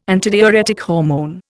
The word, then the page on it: antidiuretic hormone: Indian